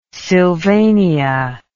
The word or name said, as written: sylvania